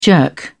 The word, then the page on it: jerk off: British English